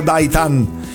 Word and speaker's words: Daitarn: Il nome si scrive Daitarn, ma si pronuncia Daitan, perché in giapponese Daitan vuol dire audace, e quindi sbagliata la pronuncia del nuovo doppiaggio.